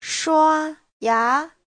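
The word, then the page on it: shuaya: shua1ya2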